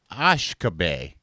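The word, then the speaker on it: uisge beatha: derived from the Gallic word, which is pronounced Ashkabe.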